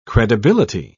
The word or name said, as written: credibility